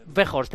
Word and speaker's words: Weghorst: Weghorst, el delantero.